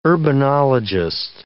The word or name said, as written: urbanologist